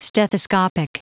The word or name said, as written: stethoscopic